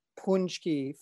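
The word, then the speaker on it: pączki: And he wrote back punchki phonetically.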